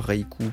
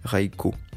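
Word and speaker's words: Raikou: Et il faut savoir que la prononciation originale serait pas Reiku comme on le prononce nous à l'européenne, mais Reiko, donc si on veut le prononcer correctement à la japonaise.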